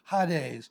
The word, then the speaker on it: Hades: You probably call it Hades, but it's a short A in the Greek, Hades.